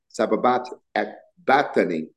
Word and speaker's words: sabachthani: It says, in about the ninth hour, Jesus cried with a loud voice, saying, Eli, Eli, lama sabachthani, is actually how it's pronounced.